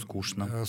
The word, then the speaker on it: скучно: Ну, десяток, может быть, полтора слов, в которых требуется произношение «ш» на месте буквы «ч». Это «что» и его производные, «конечно». Это скучно. – Скучно. Ну, вот яичница, булочная. – Двоечник. – Ну, двоечник. Но булочная уже иногда произносит и так, и так.